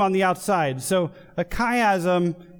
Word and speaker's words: chiasm: And it comes from the Greek letter chi, or key. So it's spelled C-H-I-A-S-M, chiasm.